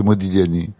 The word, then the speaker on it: Modigliani: Vous dites quand vous voulez, mais on doit pas prononcer pas le G. – Non, c'est Modigliani.